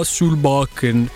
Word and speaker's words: Solbakken: Eh, e, e, tutti infatti sentivano proprio Ula uh, Solbakken perché, perché la pronuncia giusta e corretta è quella e quindi insomma eh, grazie, ci impegneremo.